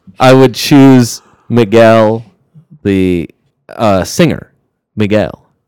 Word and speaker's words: Miguel: Miguel.